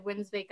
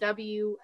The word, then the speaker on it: Wlnsvey: You know, I have a Facebook page, which is Representative Wlnsvey Campos. First name is spelled Wlnsvey.